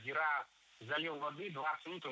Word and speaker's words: зира: В том же словаре, как и во всех остальных, другая приправа имеет ударение на первом слоге – зира.